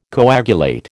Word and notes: coagulate: American English

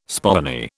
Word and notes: spoony: British English

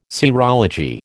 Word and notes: serology: American English

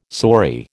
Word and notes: saury: American English

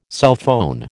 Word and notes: cellphone: American English

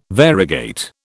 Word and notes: variegate: British English

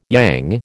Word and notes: yang: American English

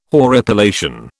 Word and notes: horripilation: British English

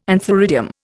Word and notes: antheridium: Indian

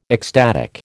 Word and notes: ecstatic: American English

